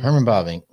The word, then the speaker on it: Bavinck: How do you say it, Bavinck? – Herman Bavinck.